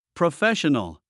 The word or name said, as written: professional